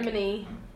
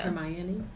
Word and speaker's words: Hermione: Hermione. – Yeah. – Hermione.